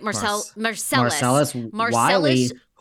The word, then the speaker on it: Marcellus Wiley: But Marcellus... am I saying it right? – Marcellus Wiley, who you met, darling.